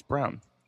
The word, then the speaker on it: Braun: Christian Brown, yeah, Brown. It's spelled B-R-A-U-N. It's not Braun, it's Brown. Go figure.